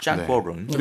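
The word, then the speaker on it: 짱구어롱 (張國榮): 우리 중국 발음으로는 짱구어롱이라고 하죠. – 짱구어롱.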